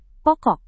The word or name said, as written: pokok